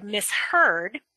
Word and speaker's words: misheard: So for example, in the word misheard, it's the unit of the end of the first morpheme of miss to the second morpheme of heard. It's because that there is that those are two morphemes that we separate the S and the H.